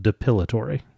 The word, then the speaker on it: depilatory: Dilapidary? – Whatever. What is dilapidary cream? – Yeah. – It's spelt depilatory.